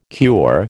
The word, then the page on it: cure: American English